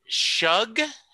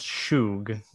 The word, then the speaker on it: Schug: Sebastian Schug, it's it's German, and people uh, people assume that it's pronounced Shug, like you know shoe on your foot, yeah.